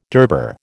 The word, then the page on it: durbar: American English